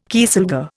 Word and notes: kieselguhr: Indian

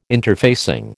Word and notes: interfacing: American English

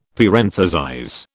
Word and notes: parenthesize: British English

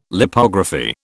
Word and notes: Lipography: British English

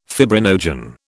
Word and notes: fibrinogen: British English

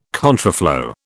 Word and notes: contraflow: British English

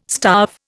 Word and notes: starve: Indian

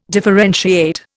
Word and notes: differentiate: Indian

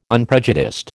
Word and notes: unprejudiced: American English

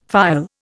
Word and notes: phial: Indian

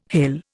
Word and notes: hell: Indian

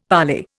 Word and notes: parlay: Indian

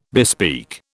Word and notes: bespeak: British English

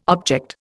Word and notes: object: Indian

